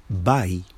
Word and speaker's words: bai: The word for go comes from Spanish. Ba. The pronunciation. But when we really see this, the word is bai, bai.